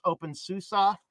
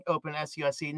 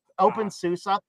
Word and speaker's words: openSUSE: But the few things you did mention, the first is the distribution pronunciation is OpenSUSE. You're saying OpenSUSE, not a big deal, but it's OpenSUSE.